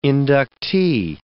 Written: inductee

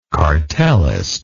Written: cartelist

